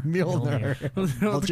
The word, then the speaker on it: Mjolnir: Mjolnir. – Mjolnir. – It kind of rhymes with wiener. Yeah. Wiener, Mjolnir.